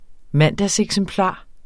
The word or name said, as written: mandagseksemplar